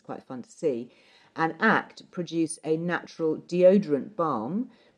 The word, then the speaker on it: AKT: called ACT. It's spelt A-K-T, and it was developed by West End Performers.